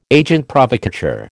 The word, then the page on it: agent provocateur: American English